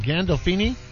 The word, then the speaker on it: Gandolfini: Gandolfini? – Yes.